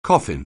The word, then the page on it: coffin: anglais